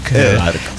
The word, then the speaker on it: ED4R: Edar.